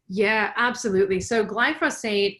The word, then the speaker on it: glyphosate: glyphosate? Glyphosate.